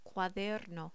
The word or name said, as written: cuaderno